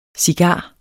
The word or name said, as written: cigar